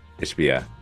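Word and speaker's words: Mat Ishbia: Matt Ishbia, is that, am I saying his name right? Tell me I'm butchering it. Ishbia. Okay.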